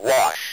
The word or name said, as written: wash